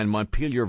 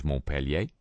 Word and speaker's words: Montpelier: Dateline Montpelier, Vermont. That's right. We changed the pronunciation when we brought it over here. It was Montpellier. But that was the French way of doing things.